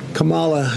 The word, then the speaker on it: Kamala: You're not allowed to criticize Kamala Harris or Kamala Harris or whatever.